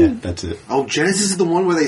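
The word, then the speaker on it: Genisys: Genesis. It's spelled fucking dumb. – Yeah, it's G-E-N-I-S-Y-S.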